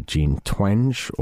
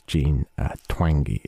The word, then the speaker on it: Twenge: But just before we do that, somebody has very helpfully told me that apparently what I had said was either Gene Twenge or Twenge is in fact more like Twangy, which makes me think of Widow Twanky from Pantomimes as a child, but it's Gene Twangy is the correct pronunciation apparently.